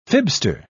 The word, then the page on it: fibster: British English